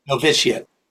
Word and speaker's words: novitiate: After his novitate, am I saying it right? – Novitiate. – Novitiate. – Yeah, that's right.